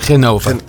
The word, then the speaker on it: Genova: Pieter Koblenz en Mario Genova. Zeg ik het goed? – Jazeker. Genova.